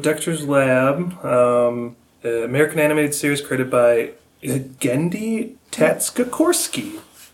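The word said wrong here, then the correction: Tartakovsky: I'm definitely not saying that right. – That's you had such confidence in your voice. – Tatskakorsky. Tartakorsky. Tartakorsky. – Yes. – Yep. – That's the guy.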